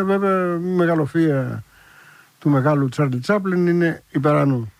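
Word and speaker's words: Charlie Chaplin: Είναι από τους μοντέρνους καιρούς του Τσάρλι Τσάπλιν, 1936, Σαρλί Σαπλέν, που λένε και οι Γάλλοι, με αυτή την άθλια προφορά.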